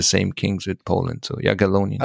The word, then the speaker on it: Jagiellonian: Jagiellonian University in Krakow.